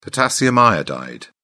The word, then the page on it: potassium iodide: British English